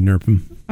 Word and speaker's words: NRMP: By the way, we pronounce NRMP NERPM.